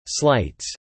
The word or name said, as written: slights